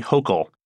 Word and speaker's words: Hochul: New York Lieutenant Governor Kathy Hochul, pronounced like local, will take over as governor.